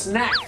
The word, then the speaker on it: snack: ของว่างต้องเรียกว่า snack สระแอดิ snack อ๋อ snack